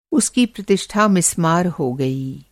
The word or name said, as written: मिस्मार